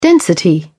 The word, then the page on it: density: British English